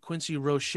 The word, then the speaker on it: Quincy Roche: I also chose Quincy Roche. That is how you pronounce it.